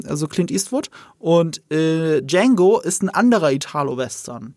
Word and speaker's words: Django: Und äh, Django mit dem Sa The D is Silent ist das Vorbild für George Lucas gewesen,